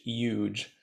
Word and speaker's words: yuj: The word yoga comes from an ancient Sanskrit word, which is Y-U-J. It's kind of pronounced like the word huge, but without the H, huge.